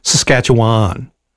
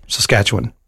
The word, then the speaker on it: Saskatchewan: Because a lot of people say Saskatchewan. They overpronounce that last syllable. – Why? What? How is it supposed to be said? – Saskatchewan. – Oh, is it? – Yeah. – I say Wan.